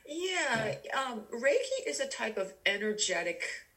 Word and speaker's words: Reiki: And, you know, on there, it's for the first time I recognized this word called Reiki, I believe is how you pronounce it.